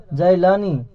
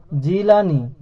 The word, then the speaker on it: জিলানি: সেই জায়গাকে সেখানকার লোকেরা জাইলানি, জিমের উপর জবর সহকারে বলে। আসল উচ্চারণ হলো জিলানি, জিমের নিচে জের সহকারে।